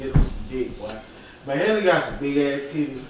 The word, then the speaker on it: Mahalia: Malia, I'm saying that right. Mahalia. Mahalia was an honorable mention.